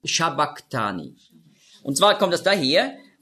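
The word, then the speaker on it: sabachthani: Und das CH im Griechischen, das ist ja ein Buchstabe, das Chi, das hat man ausgesprochen so wie ein C hinten im Gaumen. Also nicht Shabbach, sondern Shabbak. Und das repräsentiert das Hebräisch, das aramäische Q.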